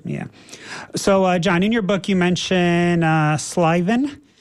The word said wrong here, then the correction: sylvan: in your descriptions. – Sylvan. – Can you please tell us about that? And can you spell it? – S-Y-L-V-A-N.